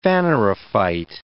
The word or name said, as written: phanerophyte